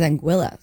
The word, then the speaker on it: Anguilla: I always wanted to say Anguilla because that's how I would pronounce it, like, in Spanish. But it is Anguilla.